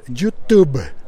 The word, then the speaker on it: YouTube: Como tan autosuficiente como como YouTube. Lo dije mal, ¿verdad? Lo dije como si fuera la banda irlandesa, pero no, es YouTube.